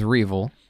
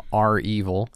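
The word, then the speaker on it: REvil: If you're just getting your cybersecurity news from us at Citadel, you'll know them as Reval. The Reval gang. – Mm-hmm. – Anybody else is calling them R-Evil, probably incorrectly. – More than, it's absolutely incorrect. – Right? – Look at how it's spelled.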